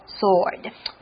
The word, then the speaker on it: sword: Oh, 你看一般我们切牛排，好，妈妈切菜的刀子叫 knife, 这是单刃的啊，这一边可以切。那如果双刃的剑的话呢，叫做 sword。Sword, 小心哦 ,S-W-O-R-D, 里面的 W 是不发音的哦，念作 sword. – Yeah, but this sword is made of energy, okay? E-N-E-R-G-Y, energy, which is power that is used to provide heat or operate a machine or run your cell phone, something like that.